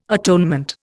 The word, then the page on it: atonement: Indian